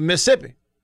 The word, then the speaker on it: Mississippi: People would not pronounce the second set of double S's. In fact, I've been known to scream about it in the past. I think the exact quote was, there are two sets of double S's, not one. And it is true. Used to bug me to death. We had a governor that would not pronounce all the S's in Mississippi.